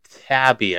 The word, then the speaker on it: Taibbi: This was released by Matt Taibbi, I believe is how you say his name on Twitter. Spelled like Matt, M-A-T-T, and then T-A-I-B-B-I, if you want to go check this out for yourself.